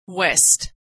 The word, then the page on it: west: w